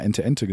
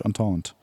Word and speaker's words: Entente: Ich habe sehr lange wirklich gedacht, das heißt Entente äh, und habe immer Ente-Ente gesagt. Ähm, wie spricht man das denn korrekt aus? – Äh, in, in, meinem schlechten Französisch Entente. Ähm, ja, ich glaube schon.